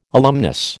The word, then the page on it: alumnus: American English